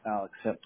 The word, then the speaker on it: Alix: So, just, same thing with the email, it's A-L-I-X. – Got it. Just wanted to make sure. Thank you. And it's pronounced Alex, right? Am I not saying? I just want to make sure I'm saying it right. – Yep, it's right. Alex. – A L I X. – Yep, it's just spelled differently. It's pronounced the same.